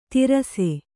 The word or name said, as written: ತೀರಿಸು